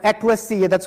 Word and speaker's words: ekklesia: Okay, that's the Greek word, ekklesia. That's how you pronounce it.